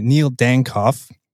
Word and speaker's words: Neil Dankoff: We're joined by Neil Dankoff.